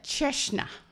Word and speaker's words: Cecina: Now I'm going to try my best stab at the pronunciation of C-E-C-I-N-A. Cecina. Turns out that word has two totally different meanings. Depending on which syllable you put the accent on.